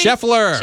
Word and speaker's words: Scheffler: Scheffler. – Scheffler. It's his Radio Mornings with Liz and Brian. He had a big weekend. – That's my wife's favorite golfer. She is a huge Scotty Scheffler fan.